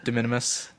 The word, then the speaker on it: De Minimis: How do I say it? – De Minimis. – De Minimis contingent.